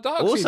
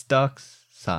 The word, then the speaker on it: Darkseid: It drives me insane when people say Darkseid. It's a weird way to say it. It's Darkseid. – Well, that is how it's spelled. – Yeah, but it's Darkseid. – Okay. – It's English pronunciation.